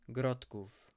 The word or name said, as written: Grodków